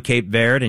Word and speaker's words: Cape Verde: Uruguay is going to be taking on Cape Verde, and yes, that's how you pronounce it in English.